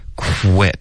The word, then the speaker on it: quit: 然后你会听到这个 w 的音呢会比较重一点比较清楚一点所以你听一下 quit 有没有听到这个 w w 的音比较重如果你用一个中式的 qu 的这个发音 quit quit 对方很有可能就只能听到 kit 就听不到这个 w 的音